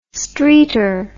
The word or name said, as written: streeter